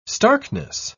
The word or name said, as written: starkness